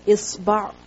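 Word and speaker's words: isba (إصبع): So asabir, it is the plural of the word isba or usbur, or there are many other pronunciations as well. And isba is finger. Alif, sad, ba, ayn. With a kasra under the alif. Isba.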